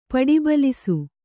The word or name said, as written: ಪಡಿಬಲಿಸು